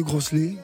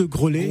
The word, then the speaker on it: Groslay: Le 30 décembre, 30 route de Grosselet. – Ouais. – bon, enfin, 30 route, de Groselet. le, disent Nous, on non, On ne prononce pas le S.